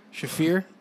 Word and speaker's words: Shafir: Marina Sheriff? – Marina Shafir. – Shafir? Shafir. Let me make sure. How you spell that? – S-H-A-F-I-R.